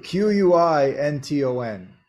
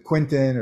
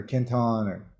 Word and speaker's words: Quinton: Some Q quin Quinton is how you pronounce it in French, Quinton. And or people here pronounce it Quentin or Quinton, but it's Quinton.